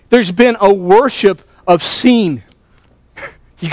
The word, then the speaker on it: Sin: They pronounced it Sin, but listen to how you spelled it. Ready? S-I-N.